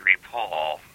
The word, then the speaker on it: rapport: Why did you just say rapport when it's spelled R-A-P-P-O-R-T? – Because the French are dumb.